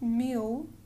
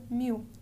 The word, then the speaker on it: mil: Strangely enough, it's because in Brazilian Portuguese, when you have an L on the end of the word, it sort of meshes into the vowel sound. So it's not pronounced mil, but rather mil.